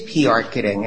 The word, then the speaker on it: PRketing: Did I say that right? Is it PRketing? – It is PRketing.